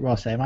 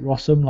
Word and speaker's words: Rossum: See, I don't pronounce it Ross M. I pronounce it Rossum, like possum.